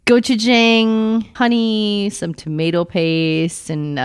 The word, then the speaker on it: gochujang: Well, I put gochujang. Did I say that right? Did I actually pronounce that right this time? – I think gochujang. – So. Or gochujang. – I usually like trip over my tongue on that. – Well done. Progress.